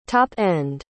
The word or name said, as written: top-end